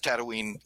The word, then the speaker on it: Tatooine: I pronounce Tatooine like Mark Hamill does, because I figure, you know, Luke grew up on it, so he knows how to say it.